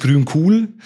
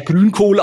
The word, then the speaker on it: Grün Cool: Ja, also meine Firma heißt Grün Cool. Wird zu 90% falsch ausgesprochen. – Deswegen habe ich es gerade nicht gesagt. – Also ich werde meistens als Herr Grünkohl angeschrieben.